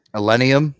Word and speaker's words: Illenium: Illium. I know I'm saying his name wrong. – Illenium.